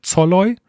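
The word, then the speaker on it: Szoboszlai: Dann, äh, weil ich es glaube ich auch falsch gesagt habe im letzten Podcast, äh, ich sage mal Scholloy, aber er heißt an, äh, anscheinend Zolloy. Also mit Z vorne oder Z gesprochen.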